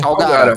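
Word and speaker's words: Kalgara: Eu não sei, é, como é a pronúncia? Calgara mesmo? Calgara, Calgara.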